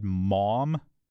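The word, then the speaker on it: Maugham: Somerset, M-A-U-G-H-A-M. I've always said mom. – Very good. It's a stretched out version of mom with the aw sound held longer than in mom.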